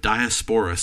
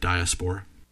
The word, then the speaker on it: Diaspora: Also, the fact that people kept mispronouncing it drove me crazy. I don't know about you, but Diaspora sounds like a medicine. – Mm-hmm. – No, people would always mispronounce it Diaspora. It sounds like a medication. – Yep. – The term is Diaspora, and I take my Diaspora very seriously.